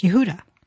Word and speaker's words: Yehuda: And of course, Judah is actually pronounced Yehuda.